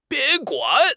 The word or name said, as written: bin1 guan3